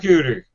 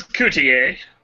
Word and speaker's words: Cooter: Jim Bob Cooter. – James Coutier. – It's pronounced Cooter.